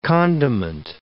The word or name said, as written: condiment